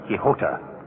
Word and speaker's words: Quixote: How do you pronounce Q-U-I-X-O-T-E? Don... – Quixote. – Quixote. Well, Webster gives two. Quixote or Quixota.